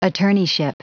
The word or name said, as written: attorneyship